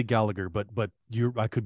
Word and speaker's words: Gallagher: And I believe it's Gallagher, I think. I don't think he pronounced the G in, I think.